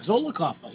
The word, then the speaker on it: Zollicoffer: Zollicoffer.